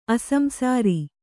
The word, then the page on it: ಅಸಂಸಾರಿ: asamsāri; Kannada